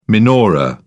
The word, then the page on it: menorah: British English